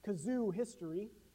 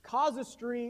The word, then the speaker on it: casuistry: There's this idea behind this term called casuistry, which you may have heard me pronounce as kazoo history before. Casuistry is this idea where we are holding ourselves accountable to the framework of Jesus Christ, his promises, and his scripture.